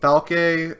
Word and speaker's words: Falke: we have a girl named Falke, who looks like Ed. – I think it's pronounced Falk. – Falk, okay.